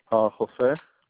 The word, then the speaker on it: Hosea: Jose. – H-O-S-E-A? – H-O-S-E-A, yeah.